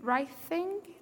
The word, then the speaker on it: writhing: 49 times it's defined as wreath, writhing in pain. Is that how you say it? Writhing, thank you.